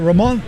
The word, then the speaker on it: Ramon: We got Ramon. Am I saying it right in French? – Yeah, that's right.